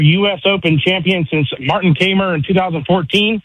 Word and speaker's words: Kaymer: Kaymer. Yeah, right. Kaymer.